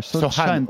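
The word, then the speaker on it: Sochan: Alors on va retourner à San Antonio, ah, puisque c'est déjà fini euh, les, quelques minutes presse de Jéré Jérémy Sotshine. – Sohan, on prononce Sohan.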